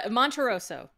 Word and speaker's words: Monterroso: Monterosso. – Monterosso. – A lot of people say Montessoro, and some people say Monterasso.